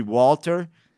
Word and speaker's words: Gwalter: His name was actually Walter, but there was a silent G in front of it. And we all pretended not to know that the G was silent, and it would make him really mad because everyone would call him Gawalter.